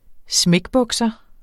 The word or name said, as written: smækbukser